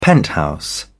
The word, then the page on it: penthouse: British English